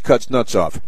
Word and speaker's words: Kuznetsov: It's K-U-Z-N-E-T-S-O-F. And I believe it's pronounced Kuznetsov because, you know, it's a Russian thing.